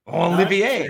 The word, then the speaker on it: Olivier: Give me how do I pronounce his name again? Olivia Maxence Prosper. – That made no sense whatsoever. Tell Olivia? You're taking Olivia. – How was my en français? – Olivier. – No, yeah, Prosper.